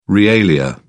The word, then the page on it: realia: British English